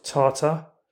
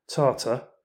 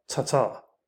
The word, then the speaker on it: Tatar: How do you actually say the word Tatar? Well, I've done a bit of research into how the word should be pronounced, and the consensus appears to be strongly in favour of Tatar, or maybe the subtly similar Tatar, but not Tatar,